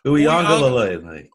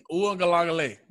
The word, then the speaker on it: Uiagalelei: Oh, it's Uiagalelei. – Uiagalelei.